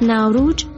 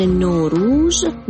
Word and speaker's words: نوروز: تلفظ نوروز در کشورهای مختلف متفاوته. از ناوروج در لکنو هند تا نوروز به توصیه احسان یارشاده. در فارسی ایران تلفظ نوروز، در افغانستان ناوروز و در تاجیکستان ناوروز استفاده میشه.